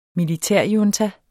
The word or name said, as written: militærjunta